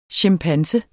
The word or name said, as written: chimpanse